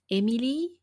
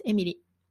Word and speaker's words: Emily: I know that learning how to pronounce Emily in when you are an English speaking person is challenging. But if you want to try, so yeah, it's Emily.